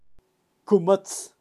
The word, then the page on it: kumatz: Kaqchikel